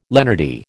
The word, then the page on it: lenity: American English